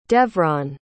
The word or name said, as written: devron